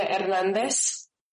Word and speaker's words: Hernandez: Talia Hernandez. – Can you please spell that for the record? – Talia, T-A-L-E-A-H, Hernandez, H-E-R-N-A-N-D-E-Z.